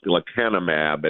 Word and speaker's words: lecanemab: Tell us all about this new drug, starting with how you pronounce the name. – Oh, goodness, you would ask me that, wouldn't you? It's lecanemab.